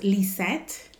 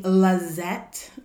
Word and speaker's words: Lisette: Okay, well, it's pronounced Lisette. – Lisette. – Okay, Lisette, cool. Like Lee, and then set it down.